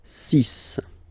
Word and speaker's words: six: And be careful here, because even if it's ending with this X, well, you'll have to pronounce it like S, S, okay? So you get the six pronunciation. Six, six. Okay, so it's not six, it's six.